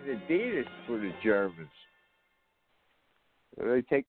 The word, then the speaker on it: Adidas: Adidas bought out Reebok, but it's pronounced Adidas. The correct pronunciation is Adidas.